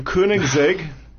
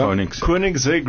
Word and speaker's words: Koenigsegg: The Koenigsegg, is that how you say it? – Not near fast enough. Yeah. – Koenigsegg Regera.